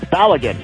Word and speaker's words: Balogun: Tony, it is Balogun.